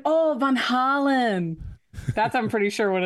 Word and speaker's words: Van Halen: Apparently, it should be pronounced Van Halen. – Just like Kelsey said, Van Halen.